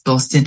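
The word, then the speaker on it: bostin: Boston. Oh, it's Boston. So Boston means brilliant, great, like, hey, in kind of Birmingham and the Black Country area.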